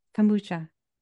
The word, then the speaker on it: kombucha: Kombucha, how do you say it? – Kombucha.